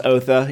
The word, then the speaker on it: Otho: Um, but it is pronounced Otha, even though it's spelled O T H O.